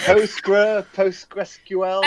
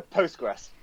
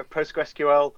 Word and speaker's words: PostgreSQL: But I always go Postgres. Some people go PostgreSQL, but it's too long.